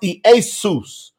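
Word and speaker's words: Iesous: and it is pronounced Iesus.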